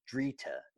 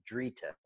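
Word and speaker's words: Dritta: And the main one, about 70% of this, is from a variety called Drita. It rhymes with Rita, but it's called Drita, D-R-I-T-T-A, I believe.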